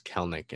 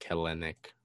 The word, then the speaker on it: Kelenic: And with the outfield prospects that you've got in, obviously, Kelnick, I keep forgetting that he said that it's pronounced Kelnick and not like Kelenic, like I would like to say.